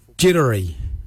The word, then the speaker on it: Djerdari: Jardak is spelt D-J-A-R-D-A-G, Jardak, grey. We'll now look at the word for brown, jittery. Jittery is spelt D-J-E-R-D-A-R-I, jittery, brown, jittery.